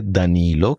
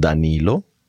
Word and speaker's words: Danilo: Ich heiße Danilo Campisi. Auf Italienisch ist es so, man betont meistens die vorletzte Silbe, deswegen mhm. – Danilo Campisi.